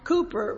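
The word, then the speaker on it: Cowper: You know, sometimes I read different books, and I was reading a book a little bit about William Cooper, which pronounce it Cooper, but in our, if you see his name, it's C-O-W-P-E-R, but he pronounced it Cooper.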